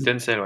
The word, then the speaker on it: Tencel: Ouais, de nylon recyclé et de, je crois que ça se prononce tencel. Si ouais, il y a du tencel, ouais, tout à fait.